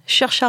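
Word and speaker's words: Saoirse: Chercha Ronan. Ah, j'adore, parce que ça s'écrit Sawars en vrai. Hein. – Donc on dit, on dit, on dit Chercha.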